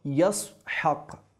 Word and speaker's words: ইসহাক: ইসহাক শব্দটির মূল উচ্চারণ হলো ইয়াসহাক, এটি ইব্রানি শব্দ যার আরবি শব্দের অর্থ হলো সে হাসছে বা সে হাসতেছে।